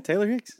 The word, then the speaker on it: Taylor Hicks: Taylor Hicks. Am I saying his name right? – Yeah, Taylor Hicks.